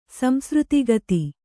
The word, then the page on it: ಸಂಸೃತಿಗತಿ: Kannada